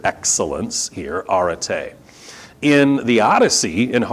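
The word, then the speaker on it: arete (ἀρετή): If you care, it's pronounced arete.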